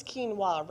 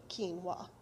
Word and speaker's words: quinoa: if you look at the box, it says quinoa. It does. That's how it's spelled. Q U I, right? N O A. And so that's what I was calling it because I didn't know better. Until a sweet friend said to me, You know, it's quinoa, right? And literally showed me where on the box it says how to pronounce it quinoa.